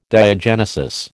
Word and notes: diagenesis: American English

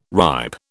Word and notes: ripe: British English

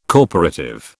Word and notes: corporative: British English

